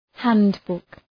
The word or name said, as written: handbook